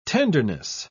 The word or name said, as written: tenderness